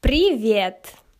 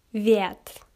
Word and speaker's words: Привет: Listen carefully and repeat. Привет. При. Привет. Привет. Привет.